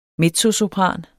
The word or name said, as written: mezzosopran